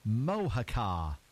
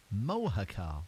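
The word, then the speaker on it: Mojácar: So it's an H. The J is pronounced as an H. Mohacar. Mohacar.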